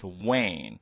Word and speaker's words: Wang: You see the Bond, B-O-N-D. Is that right? – Yeah. – And my last name W-A-N-G. Most American probably they pronounce Wang, but the actual pronounced name for our Mandarin, our dialect, we pronounce Wang.